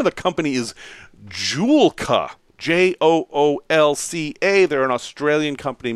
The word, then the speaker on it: Joolca: Joolca. J-O-O-L-C-A.